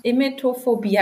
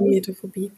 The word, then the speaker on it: Emetophobie: Ja, du hast Emetophobie. Habe ich das richtig ausgesprochen? Genau, richtig. – Ja, das Emetophobie.